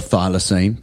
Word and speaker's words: thylacine: We have a projection up of a thylacine. Did I say that right, Andrew? – You did, yeah.